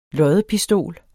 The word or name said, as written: loddepistol